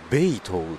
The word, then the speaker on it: 北投: ベイトウって読むんですね。